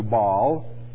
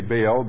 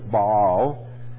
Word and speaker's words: Baal: Yet I have left me 7,000 in Israel, all the knees which have not bowed unto Baal, is the correct pronunciation. We say Baal, but it's Baal.